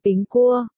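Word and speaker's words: bình cua (苹果): Trong tiếng Trung, quả táo được phát âm là bình cua.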